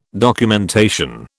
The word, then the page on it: documentation: British English